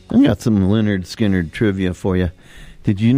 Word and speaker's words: Lynyrd Skynyrd: They pronounce it Lynyrd Skynyrd.